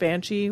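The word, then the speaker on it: bean sídhe: It's spelled Bean City, but it's pronounced Banshee, which is odd.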